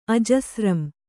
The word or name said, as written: ಆಜಸ್ರಂ